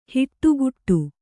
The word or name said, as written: ಹಿಟ್ಟುಗುಟ್ಟು